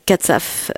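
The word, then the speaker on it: Kacew: Il s'agit du roman publié par Romain Gary, de son vrai nom Romain Katsaf, pour la prononciation polonaise, en 1958, et qui s'intitule « La promesse de l'aube ».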